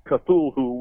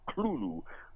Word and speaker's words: Cthulhu: Now, a lot of people pronounce it as Cthulhu, which is absolutely wrong, but it's very difficult to get people to correct that pronunciation. Lovecraft, in a letter, clearly states that it's a two-syllable word. The TH at the beginning there is kind of like a guttural L. It's like Cthulhu, but no matter.